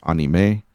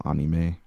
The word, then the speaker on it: anime: Well, it's because anime is a French word, and so obviously in the Romance languages, it's all, you know, it's all short. – Gotcha. – A-E-A-O-U, so it's anime. And actually, it's pronounced that way in Japanese as well, anime.